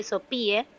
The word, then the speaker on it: Sophie: Recuerden que mi nombre se escribe Sophie, S-O-P de papá, H-I-E, Sophie Oliver.